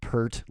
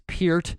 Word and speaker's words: Peart: It is not Pert like the shampoo. It is Peart. Neil Peart of Rush and Vinny Appice.